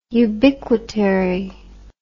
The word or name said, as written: ubiquitary